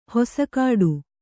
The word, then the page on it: ಹಸಗೆಡು: hasageḍu